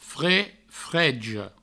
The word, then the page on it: freg, freja: fre, frejo